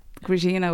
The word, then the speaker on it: Grazina: People used to say, oh, Grazina. Yeah, but Grazina would be my real name.